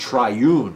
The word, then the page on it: triune: American English